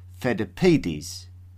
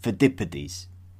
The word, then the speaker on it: Pheidippides: So the famous Athenian runner who was central to the story of the marathon race, I pronounced as Pheidippides, instead of the more familiar Pheidippides.